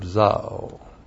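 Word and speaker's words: Zao (ζάω): But with regard to the resurrection life that he lives, and that is the verb zao. The A long O, Zao.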